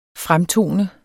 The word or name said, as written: fremtone